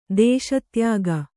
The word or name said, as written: ದೇಶತ್ಯಾಗ